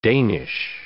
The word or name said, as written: Danish